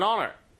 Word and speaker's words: honor: 아시겠지만 이 H는 묵음입니다. It is an honor.